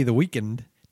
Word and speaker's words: The Weeknd: If you're gonna be The Weeknd, do it outside the studio. – The Weeknd? – Yeah. – Is that how you pronounce it? – Yeah. That's how it's spelled. That's how it's pronounced.